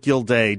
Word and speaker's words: Gilday: I think he pronounces it Gilday, G-I-L-D-A-Y, I think, and if I'm wrong, okay.